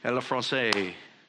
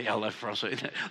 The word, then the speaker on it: le français: Le français. I didn't say that right, did I say? Le français. Le français? Yep, just stop.